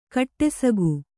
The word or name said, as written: ಕಡುಸೊಗ